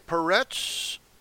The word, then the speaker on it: Peretz: Now, I'm using the proper Hebrew pronunciation for the names. I'm not anglicizing anything. It's wrong. It's always been wrong. And I'm not going to repeat those errors. I'm going to say it right. Okay, Peretz and Zerach by Tamar.